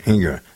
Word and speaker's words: Engoron: Engoron, is that how you pronounce his name? – I think it's right. – Engoron, yeah.